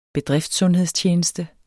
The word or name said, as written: bedriftssundhedstjeneste